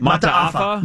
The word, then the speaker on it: Mata'afa: Mata'afa.